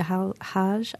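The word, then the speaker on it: AlHaj: In answering sort of both of those questions, Rahima Al-Haj, I think Al-Haj, I think you say his name, he plays the Iraqi oud, which is, for me, I'm a Midwesterner, an exotic instrument.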